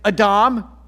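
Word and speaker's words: Adam (אדם): We pronounce it in English as Adam, but the Hebrew word there is Adam, and Adam in Hebrew means human.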